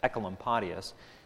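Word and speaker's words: Oecolampadius: A colleague of Zwingli, who holds to Zwingli's view, a man named Ecolampadius, and this is the hardest name of the reformer to pronounce, hardest reformer's name to pronounce, Ecolampadius, which is O-E, by the way, O-E, Ecolampadius, who was the leading reformer in Basel.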